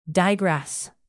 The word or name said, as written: digress